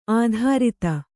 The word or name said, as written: ಅಧರಿತ